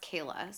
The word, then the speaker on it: Kala: Okay, and this person, her name is spelled K-A-L-A, but I heard it pronounced Kayla, so I'm going to say Kayla.